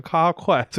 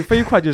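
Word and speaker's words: 啡快: 外送的一个门店叫飞快，然后截止到今年八月呢，已经有一百四十多家了。 – 这里我忍不住要插一个自己的吐槽，就是我经常会把这个门店念成咖快，所以飞快就是飞快，它是一个谐音梗，它是速度飞快的意思啊。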